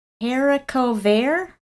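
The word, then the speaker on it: haricot vert: Haricover? – That's how I would say it. Two words. H-A-R-I-C-O-T space V-E-R-T.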